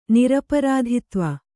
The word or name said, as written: ನಿರಪರಾಧಿತ್ವ